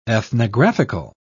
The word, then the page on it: ethnographical: British English